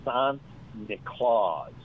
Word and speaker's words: Sint-Niklaas: So the Dutch pronunciation of St. Nicholas is Sint-Niklaas or Santa Claus.